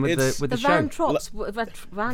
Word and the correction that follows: Von Trapp: Von Trapp.